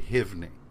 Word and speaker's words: Suibhne: Even though the character, it's spelled S-U-I-B-H-N-E. – Yeah. – But B-H in Gaelic is a V sound, like yeah. in Siobhan. – Yeah. – So his name was actually Hivni.